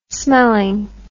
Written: smelling

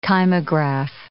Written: kymograph

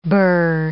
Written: birr

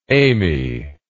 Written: Amy

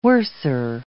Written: worser

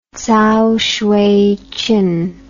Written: Cao Xueqin

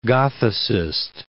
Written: Gothicist

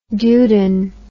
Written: Duden

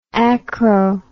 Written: acro

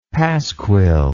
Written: pasquil

